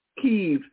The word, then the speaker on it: Kyiv: Kyiv, Saturday, to meet with Ukrainian President Vladimir Zelensky.